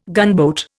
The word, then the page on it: gunboat: Indian